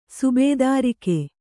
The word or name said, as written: ಸುಬೇದಾರಿಕೆ